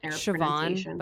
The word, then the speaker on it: Siobhan: It's like Siobhan, but it's spelled Sobian. It's Irish. – Yeah, exactly. It's Irish. Irish name.